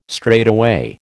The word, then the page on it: straightaway: American English